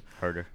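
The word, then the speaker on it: Huerter: They still got Kevin Huerta. I don't know if I pronounced that correctly, but that dude's deadly from the three. Herder, thank you. – Herder.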